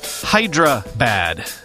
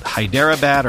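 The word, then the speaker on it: Hyderabad: Also, yes, it's Hydra bad, not Hydera bad or whatever it is that I said.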